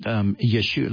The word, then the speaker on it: Yeshua: And you know, Yeshua, let's go to Yeshua, which is the name of Jesus, the Hebrew pronunciation.